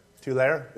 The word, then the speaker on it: Tulare: Is that pronounced Tulare? Is that how you pronounce T-U-L-A-R-E? Tulare? It's Tulare? Okay.